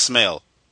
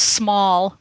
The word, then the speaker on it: Smale: it's pronounced It's pronounced Smale.